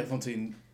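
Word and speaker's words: Fantine: Am I saying that right, Fontaine? – Yeah, Fontaine, which is Cosette's mum.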